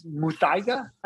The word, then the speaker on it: Muthaiga: So I watched it's Muthaiga, I think it's the best way to pronounce it, Miami Muthaiga Golf Club.